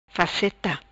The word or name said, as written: Faceta